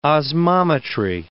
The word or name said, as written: osmometry